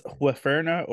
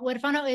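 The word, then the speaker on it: huérfano: That's Huerfana or something. – Oh, Huerfano. Huerfano is it means orphan in Spanish.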